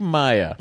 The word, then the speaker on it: Mia: My wife, Mia, pronounced like Maya.